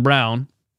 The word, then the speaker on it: Braun: Physical, with purpose, taking the ball, squaring up against smaller defenders like Scooter Braun, uh, Christian Braun. I call him Scooter Braun because his name's not Brown. It's not spelled like Brown.